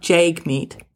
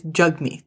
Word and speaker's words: Jagmeet: And he would make it a point to be like, no, sorry, my name's not Jagmeet, it's Jagmeet. And there was one reporter I remember, and he kept saying, Jagmeet, Jagmeet, Jagmeet.